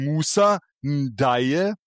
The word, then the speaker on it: Musa Ndiaye: Einmal ein Musa Ndiaye, spricht man ihn anscheinend aus. Ich habe extra nachgeschaut.